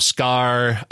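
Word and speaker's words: Skaar: We got the introduction of Scar. And I said Scar. That's not right. – Yeah. – Is this Scar? Right. Hulk's son? Is that? I'm sorry. When I said Scar, I immediately thought of Lion King.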